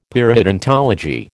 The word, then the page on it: periodontology: American English